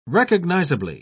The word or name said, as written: recognizably